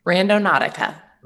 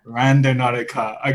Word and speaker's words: Randonautica: Randonautica. – Randonautica. Okay, and Simon, you as well? – With my New Zealand accent, it's Randonautica.